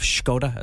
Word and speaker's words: Skoda: If you have a Skoda, a new enough Skoda, as they're actually correctly pronounced, look up at your rear view mirror.